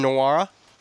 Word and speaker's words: Nwora: They're led by Jordan Nwora, right? Did I do that right? Did I say that right? – Yes, you did.